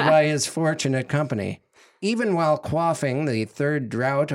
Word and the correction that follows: draught: of the Fountain of Youth, or draft, probably. – I said it wrong.